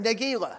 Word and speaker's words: Nagila: Now let's say it like David wrote it, Nagila. – Nagila. – There you go.